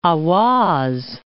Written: Ahwaz

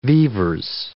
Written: vivers

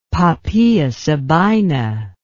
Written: Poppaea Sabina

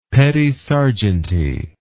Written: petit serjeanty

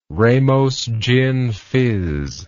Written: Ramos gin fizz

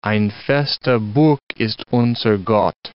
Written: ein' feste Burg ist unser Gott

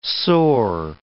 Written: soar

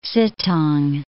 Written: Sittang

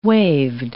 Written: waved

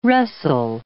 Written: wrestle

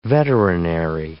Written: veterinary

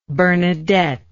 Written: Bernadette

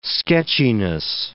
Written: sketchiness

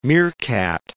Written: meerkat

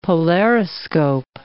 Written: polariscope

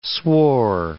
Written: swore

